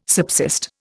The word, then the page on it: subsist: Indian